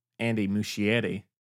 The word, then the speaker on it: Muschietti: One thing I will say that I don't think is being appreciated enough about Andy Muschietti, that's how you say his name, is he'll feel appreciated.